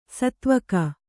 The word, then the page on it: ಸೇದೆವುಗು: kannada